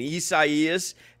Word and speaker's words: Isaias: With the, like, I've practiced pronouncing this hurricane, Isaias. And then yesterday I called it Hurricane Isaiah. I in the moment I looked down at my notes, it became Isaiah.